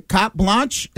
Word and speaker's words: carte blanche: So I think there will be something along those lines because they give her, what do they say, carte blanche? Is that how you say it? – Yep.